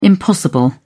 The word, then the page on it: impossible: British English